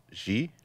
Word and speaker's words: Xi: And she, is that how you say his name? She? Z? XI. How do you say that? – I think it's Xi.